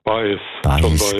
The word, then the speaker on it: Baez: Sagt mir der Joan Baez oder Baez? – Baez, gell? Ja. – So. – Latinisch ausgesprochen.